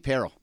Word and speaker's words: Parrell: Keith Parrell. – Rhymes with Pete Carroll.